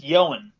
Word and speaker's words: Ioan: First name, I O A N, pronounced Yoan.